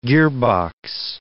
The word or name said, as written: gearbox